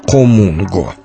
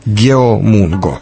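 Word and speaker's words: хомунго (거문고): Одно время меня очень удивляло, как пишется латиницей название корейского традиционного музыкального инструмента хомунго. Геомунго. Если прочесть так, как на основных европейских языках, то будет, как я уже сказал, геомунго.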